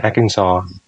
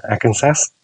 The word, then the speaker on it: Arkansas: hay là như là bang Arkansas, đọc như thế chứ không phải là đọc là Arkansas chẳng hạn gì.